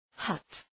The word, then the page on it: hut: {hʌt}